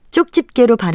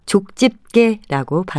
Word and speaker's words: 족집게: 쪽집게로 발음하는 경우가 많아서 첫 번째 음절에 쌍지읒을 쓰는 것으로 알고 계신 분들이 많을 것 같은데요. 지읒을 쓰고 족집게라고 발음합니다.